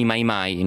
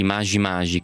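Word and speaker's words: Maji Maji: Nel tuo libro racconti degli incontri con alcuni mai mai, ne ho parlato prima, sono queste milizie partigiane, anzi bisognerebbe dire, secondo la pronuncia swahili, magi magi, che vuol dire acqua.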